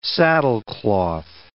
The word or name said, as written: saddlecloth